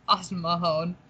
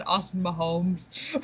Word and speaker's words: Austin Mahone: I said that wrong. Austin Mahone. I think I said it wrong earlier too. I think I said Austin Mahomes.